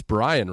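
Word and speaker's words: Bryon: I learned last night his name wasn't Byron. I thought that since the mid-90s that it was Byron Russell. And last night I was convinced everyone was saying his name wrong out of disrespect. Nope, it's Brian Russell.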